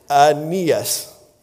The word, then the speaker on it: Aeneas: So it's Aeneas. You got to get the short A sound there, okay? Peter found a man named Aeneas who was paralyzed and had been bedridden for 8 years.